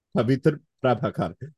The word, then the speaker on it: Pavitra Prabhakar: Pavitra Prabhakar. Yeah. Pavitra Prabhakar. Which kind of sounds like Peter Parker.